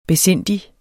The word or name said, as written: besindig